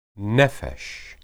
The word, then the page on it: nephesh: hebrew